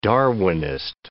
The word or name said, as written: Darwinist